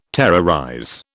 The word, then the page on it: terrorize: British English